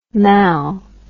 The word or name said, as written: mal